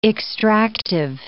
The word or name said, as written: extractive